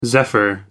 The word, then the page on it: zephyr: American English